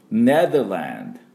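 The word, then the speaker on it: Netherlands: 松山千春さん、ね、福山雅治さんの、ロ、リ、ロ、ラ、ロ、で、ロ、で、ランド、ランド、ネザーランドって言うと綺麗な、ネザーランドの音になります。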